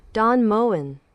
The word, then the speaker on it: Don Moen: Don Moen. – Moen, es como Moen. La E final es como ahogada, ¿verdad? Don Moen. Rarísimo. Es que la N tiene su propia vocal intrínseca. Don Moen.